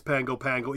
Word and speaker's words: Pago Pago: The Samoans have kept that N off, so it is Pango Pango, even though it's spelled Pago Pago.